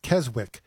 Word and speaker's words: Keswick: Then we went to the Lake District in England, to Keswick, which I discovered, much to my surprise, is not pronounced Keswick. The W is silent.